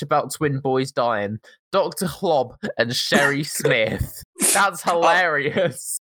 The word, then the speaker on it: Hloob: And by Dr. Klob, I mean it's spelled H-L-O-O-B. So it is actually Dr. Klob and Sherry.